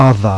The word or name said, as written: thorn